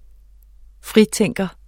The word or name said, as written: fritænker